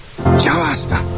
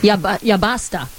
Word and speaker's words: ya basta: Ya basta. – It turns out he doesn't speak any Spanish. – He mispronounced it.